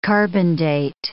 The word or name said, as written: carbon-date